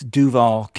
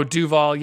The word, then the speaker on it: Duval: But it's Duval County. – Oh, Duval.